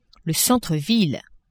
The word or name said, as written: centre-ville